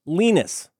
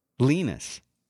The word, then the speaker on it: Linus: How do you say his first name? – Linus Torvalds. – Nope. Nope. – How do I say it? – Linus. – Linus. – It's true.